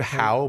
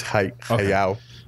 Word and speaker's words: Hayao: I've heard Hao, but I think it's Hayao.